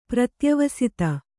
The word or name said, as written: ಪ್ರತ್ಯವಸಿತ